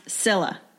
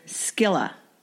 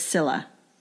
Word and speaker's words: Scilla: And we should point out, so Siberian squill, the genus name is S-C-I-L-L-A, which is pronounced Scylla. A lot of people want to say Scylla, but it's Scylla. Silent C.